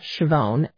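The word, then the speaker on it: Schiavone: And if you search under faculty and staff, my name is Anne Chavone, A-N-N, and the last name is spelled S-C-H-I-A-V as in Victor, O-N-E.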